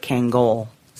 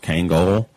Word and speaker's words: Kangol: I believe it is pronounced Kangol, but no one says that.